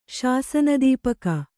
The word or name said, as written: ಶಾಸನದೀಪಕ